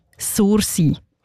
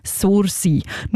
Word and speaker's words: Sursee: Einmal so aussprechen, wie ein Einheimischer oder eine Einheimische das würde. Und ich komme ja selber auch von dort und zeige ich es euch jetzt schnell mal vor. Sursee. Nicht anders, Sursee.